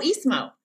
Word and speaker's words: Ismo: I forget how to pronounce his name. It's like Islo or something. Oh, Ismo. He's Ismo.